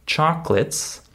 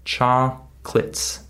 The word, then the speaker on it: chocolates: So the last word I said, chocolates, notice that it's just two syllables. Chocolates, right? We don't say chocolates. That's how my students say this word, but that's not how native speakers say this word. We skip the middle syllable.